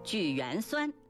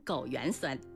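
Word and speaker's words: 枸橼酸: 枳缘酸，差点被我说成枸缘酸。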